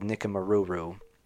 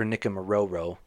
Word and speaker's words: Nikumaroro: So the theory here is that she was actually closer, about 400 miles away, near an island called Nikumaruru, or Nikumaroro, not sure how it's pronounced, mm-hmm. I think it's Ruru.